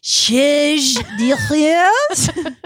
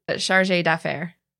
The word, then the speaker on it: chargé d'affaires: Charge d'affaires? – It is quite shocking how bad. – Okay, well, what is it? What I'm doing it phonetically. What is it? Chargé d'affaires. – Chargé d'affaires? – I mean, I was supposed English.